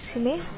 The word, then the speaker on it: Simi: Hi, my name is Simi.